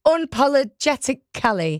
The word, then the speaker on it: unapologetically: Inapologetically. See, I've said it then. And this is because... I said it wrong. I said, I'm just getting... The producer in my ear saying it's in... Unapologetically.